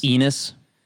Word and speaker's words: Enes: My name's Enes. – Okay, Enes. I don't recall him. – Rhymes with a Venus.